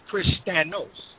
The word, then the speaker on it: Christianos: it would have been pronounced as Christianos with a O-S.